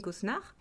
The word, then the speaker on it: Cosnard: Alors pour me trouver, j'ai une page LinkedIn à mon nom, donc Amandine Cosnard, et j'ai également un site internet par lequel on peut me joindre.